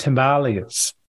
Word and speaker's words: Himalayas: So I've heard Himalayas as well as Himalayas before. – Yeah. So from what I understand, it's meant to be pronounced Himalayas.